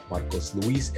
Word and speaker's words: Marcos Luis: One Mic Night is spelled O-N-E-M-I-C-N-I-T-E. And you can follow me at Marcos Luis Everything, M-A-R-C-O-S-L-U-I-S.